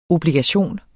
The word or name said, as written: obligation